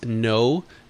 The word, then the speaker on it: Kno: I would probably look at No. It's spelled K-N-O.